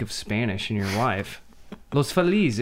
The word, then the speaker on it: Los Feliz: I would originally pronounce it like the correct Spanish pronunciation, which would be Los Feliz, but that's not at all. Because it's, I mean, you pronounce Los Los, so it's Los Feliz. And Mexican people cringe.